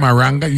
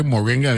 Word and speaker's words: moringa: You pronounce it the right way. It ain't maranga. You say moringa. It ain't maranga, man. In a M-A. – I know whenever I hear that there. – M-O-R-I-N-G-A. – M-O-R-I-N-G-A is how it's spelled.